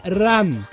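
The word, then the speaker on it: ran (乱): A letra do primeiro kanji é ran. – Não, é ran. – Ran, ran. Com R fraco, como se fala barata, né? Então, ran, ran.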